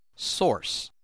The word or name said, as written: source